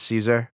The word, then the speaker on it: Caesar: It's the proper way to pronounce it. Fun fact for the history nerds. Rome, Latin. – Yes. – Doesn't do soft C's. – Oh. – So the proper way to pronounce Caesar is Caesar.